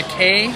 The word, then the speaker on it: kei: this fire truck is what's called a K car. – Yes. – In Japan, we had them in the U.S. for a while. – It's spelled K-E-I. – Okay, K-E-I.